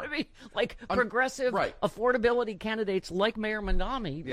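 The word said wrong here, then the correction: Mamdani: Yeah. – Mondani, sorry.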